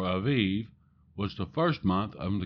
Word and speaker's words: Aviv: You see, in Exodus chapter 13, verse 4, it says, "On this day of the month of Abib," that's A-B-I-B, but it's actually pronounced Aviv, "you are about to go forth."